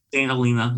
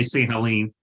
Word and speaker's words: Helena: And that is how you pronounce it, Helena, right? – St. Helena, yeah. – Yeah, just want to be sure. – A lot of people say St. Helene.